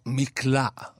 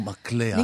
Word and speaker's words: מקלע: אז אני שומע לפעמים מצנח. – ולא מצנח. – ולא מצנח. מקלע. ולא מקלע.